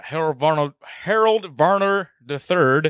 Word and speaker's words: Harold Varner III: I'm going to pour one out for Harold Varner III.